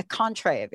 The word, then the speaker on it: Contrave: Contrave. Is that correct? Did I say that correct? – You said it correctly.